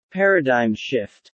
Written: paradigm shift

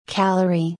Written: calorie

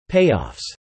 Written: payoffs

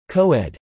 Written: Co-ed

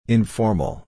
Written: informal

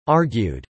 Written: argued

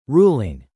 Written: ruling

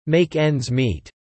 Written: make ends meet

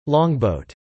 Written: longboat

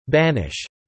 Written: banish

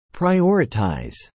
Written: prioritize

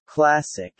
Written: classic

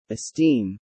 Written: esteem

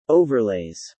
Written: overlays

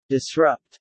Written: disrupt

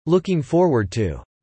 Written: looking forward to

